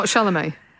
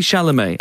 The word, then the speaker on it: Chalamet: He's got an accent on the E. – He does. He does. The accent makes it... It can't be Chalamet.